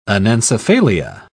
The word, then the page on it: anencephalia: British English